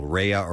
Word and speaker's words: Raya: By the way, the app is R-A-Y-A. That's how it's spelled, Raya or Raya.